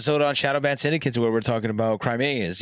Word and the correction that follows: chimeras: you know what I mean? Crimeas. Not, I was saying it wrong on the episode. I forgot what I was saying, but I'm not going to say it again because I'll lose it. – Chimeras. – But these, yeah, chimeras.